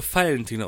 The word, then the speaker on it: Valentin: In Bayern wird der Name Valentin ausgesprochen. Mit Vogel V.